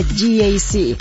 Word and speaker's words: GAC: Se escribe GAC, pero se pronuncia GAC. Repítelo, GAC.